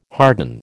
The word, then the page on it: harden: American English